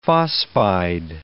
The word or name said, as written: phosphide